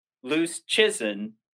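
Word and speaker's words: Luszczyszyn: He said it's like loose chicken. So it's Dom Loose Chisholm. So he's, I've been saying the Chisholm for the longest time, but it's loose chicken.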